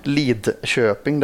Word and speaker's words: Lidköping: Oklart varför man inte uttalar det, men Lidköping där.